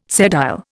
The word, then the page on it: sedile: Indian